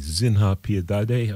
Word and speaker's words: Piedade: It's spelled P-I-E-D-A-D-E. Zinha Piedade, I believe the pronunciation is.